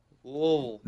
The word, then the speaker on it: Louisville: Louisville. – No, you got to say it like you're constipated.